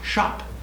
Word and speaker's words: scop: S-C-O-P is pronounced shop.